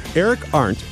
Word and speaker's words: Eric Arndt: Eric Arndt, I believe I'm saying that right.